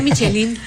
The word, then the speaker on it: Michelin: Oye, que esa es la pronunciación correcta, porque todo el mundo, la guía Michelin, Michelin.